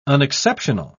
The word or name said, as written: unexceptional